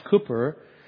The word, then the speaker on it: Cowper: His last name is spelled C-O-W-P-E-R, but it's pronounced Cooper.